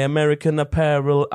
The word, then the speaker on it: American Apparel: Ja, es gibt doch auch in diesem einen Song, She looks so perfect standing there in my American Apparel underwear. Kennst du den Song? – Mhm. – Ich glaube, die Firma gibt's ja. Wie habe ich das ausgesprochen? Top.